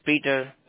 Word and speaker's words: Peter: My name is Peter.